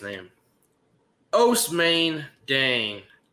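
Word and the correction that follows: Ousmane: I think it's Usman, but I'm right there with you.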